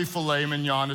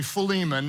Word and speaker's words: Philemon: We're going to study the book of filet mignon in the New Testament, which is not really filet mignon, it's really Philemon.